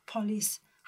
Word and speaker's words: polis: 在马来语呢，警察真正的是叫做 police，它跟英文的发音是一样的，只是英文是 p o l i c e，那马来语呢是 p o l i s，所以警察叫做 police。